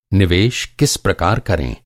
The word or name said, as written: किस